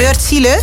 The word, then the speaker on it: Wärtsilä: Deze moet eind volgend jaar operationeel zijn en wordt gebouwd in samenwerking met het bedrijf Wärtsilä. Zeg ik dat goed? – Ik heb geen idee. – Wärtsilä. Ja. Met de A met puntjes erop.